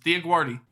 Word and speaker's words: DioGuardi: Not quite sure how the first name's pronounced, but it's Diaguardi.